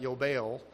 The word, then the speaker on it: yobel: I would pronounce that yobel, but you can hear the opening yod is usually changed to a J in our Germanic, from our Germanic roots, jubel, and then we put an E on the end of it.